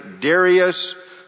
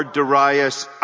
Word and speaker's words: Darius: let me just say, Darius or Darius, either pronunciation is considered acceptable. I use Darius.